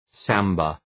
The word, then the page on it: samba: ‘sæmbə